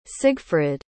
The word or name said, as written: sigfrid